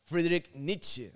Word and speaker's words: Friedrich Nietzsche: Friedrich Nietzsche.